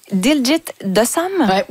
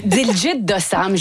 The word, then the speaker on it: Diljit Dosanjh: Et on va commencer cette fois-ci avec le chanteur indien Diljit Dosanjh. – Ben c'est comme ça que je le prononce aussi. Diljit Dosanjh, je ne sais pas si vous l'avez croisé ou entendu.